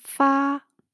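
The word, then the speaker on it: 파 (发): 오늘은 동사 파, 파 일상입니다. 한국말로는 발자죠. 그쵸? 솔 발자. 발. 어, 그 중국어 발음으로는 일성을 유지하시면서 파라고 발음이 납니다.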